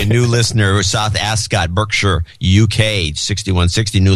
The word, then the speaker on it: Berkshire: It's pronounced Berkshire, not Berkshire.